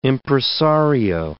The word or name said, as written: impresario